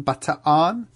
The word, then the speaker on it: Bataan: Bataan? – The correct Filipino pronunciation is Bataan. They pronounce every syllable, but in America, 9 times out of 10, we'll say Bataan.